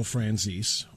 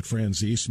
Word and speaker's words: Franzese: You probably have heard the name of Michael Franzese, or Franzese. You may have heard it pronounced that way as well. I've always heard it and pronounced it Franzese.